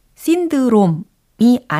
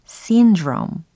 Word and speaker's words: syndrome: 바로 S-I-N-D-R-O-M-E 라는 철자의 단어입니다. 어떤 발음일까요? 신드롬이 아닌 syndrome 이렇게 하시면 되는 거죠. syndrome, syndrome, syndrome.